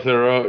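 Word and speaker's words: Theroux: Thero? – Oh, Thero, yeah.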